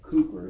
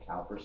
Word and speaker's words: Cowper: Spurgeon, George Whitfield, William Cowper, Cooper is the way it should be pronounced, and most people pronounce it Cowper, C O W P E R, William Cooper, the hymn writer.